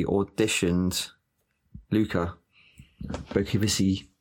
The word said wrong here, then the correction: Bercovici: Bercovici. Bercovici